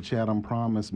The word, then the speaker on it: Chatham Promise: Am I saying that correctly, Chatham Promise? – Absolutely.